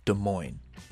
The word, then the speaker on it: Des Moines: Now let's take a beer break and talk about the name Des Moines. We've joked about the silent S's a few times throughout these Des Moines episodes.